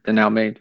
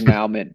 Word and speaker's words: denouement: The French word for, I always want to say it wrong because my East Texas comes out every time I look at it. But I want to say it's denouement, but it's not. It's like denouement.